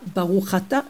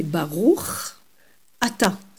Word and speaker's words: Baruch Ata: Alors, bien prononcer les mots et les séparer. Baruch Ata, pas Baruch Ata, mais Baruch Ata. C'est deux mots bien distincts.